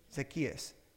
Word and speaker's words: Zacchaeus: The other one would be, help me with the pronunciation, Zacchaeus? Zacchaeus, yes.